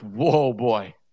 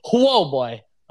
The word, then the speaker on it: Huobi: It's also a really interesting timing, considering there's a lot of rumors right now of Huobi being insolvent, one of the more popular Eastern exchanges. And I think it's pronounced whoa boy. – Yeah, whoa boy, also potentially being in danger.